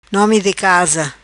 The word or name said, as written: Nomi Di Casa